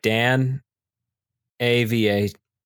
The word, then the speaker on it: Danava: It's D A N like Dan A V A, and I believe most people would pronounce that Danava.